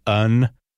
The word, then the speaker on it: yn: The letter Y at the beginning or in the middle of a word in Welsh is usually pronounced like a schwa sound, like the unstressed the as in above. Examples include the word YN, which is in. So YN would be more like un, and yes.